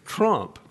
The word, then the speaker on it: Trump: Now, how do you pronounce the last name? Troop? Is that his name? Troop? No, Trump. Trump.